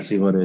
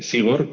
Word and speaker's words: Zigor: Que eh, Sigor, que se escribe como Igor pero con una Z por delante, en todos los idiomas menos en el castellano se envía Sigor y sin embargo en español pues se dice Sigor, ¿no? Entonces es el el único país en el que, claro, sin saber cómo se pronuncia en euskera, lo, lo pronuncian mal, ¿no?